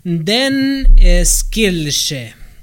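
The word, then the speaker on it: Den skyldige: En danés se escribe y se pronuncia Den Skilje.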